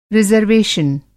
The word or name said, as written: रिज़र्वेशन